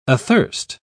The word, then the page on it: athirst: British English